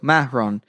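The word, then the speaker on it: Marron: There's two R's and it's more like it's like a pause between the ma sound and the ron sound. – Yeah. – So Mahron.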